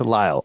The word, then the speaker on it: Lisle: And it just moved to Lisle, although it looks like Lisle. So right. It's it's weird. A silent S, which is confusing, which is hard.